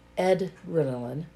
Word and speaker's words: EDrenaline: it's not adrenaline like adrenaline, like the science. Edrenaline. E-D.